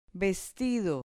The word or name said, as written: vestido